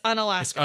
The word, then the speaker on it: Unalaska: It's so Alaska, it's Unalaska. – It's Unalaska. And it's spelled exactly like you think it is. – According to the internet, that is how it's pronounced.